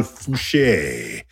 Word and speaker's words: Fouché: And of course, we've got Professor Rayvon Fouché. That's an E with an accent, in case you didn't know how to pronounce the trailing E.